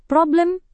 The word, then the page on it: problem: Indonesian